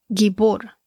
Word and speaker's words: gibor: En la traducción hebrea, la palabra se pronuncia más o menos así como gibor, G-I-B-O-R, que significa poderoso, tirano, también significa gigante.